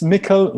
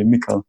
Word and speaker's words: Mikkel: So just Mikkel with without the. It's like I wouldn't have a J. So some people say Mikkel J. So it's just a silent J basically, Mikkel.